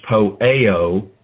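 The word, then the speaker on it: poieo: Again, you've got A-I-O-N. It's not eternal. It's the purpose of the ages, which he accomplished, which is poeo, P-O-I-E, long O, in Christ Jesus our Lord.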